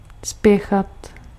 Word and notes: hurry: IPA: /ˈhʌ.ɹi/

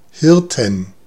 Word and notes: Hirten: [ˈhɪʁtn̩]

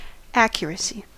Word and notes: accuracy: American English; /ˈæk.jɚ.ə.si/

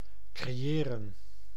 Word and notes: creëren: /kreˈjeːrə(n)/

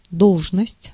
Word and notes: position: /pəˈzɪʃən/

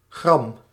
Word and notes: rage: France; [ʁaʒ]

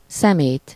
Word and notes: ordures: [dɛ.z‿ɔʁ.dyʁ]; /ɔʁ.dyʁ/

